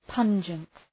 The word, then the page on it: pungent: ‘pʌndʒənt